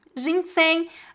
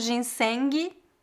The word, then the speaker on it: ginseng: Ginseng, essa é uma palavra interessante porque termina com a letra G, mas nós não pronunciamos ginseng. Não, é ginseng.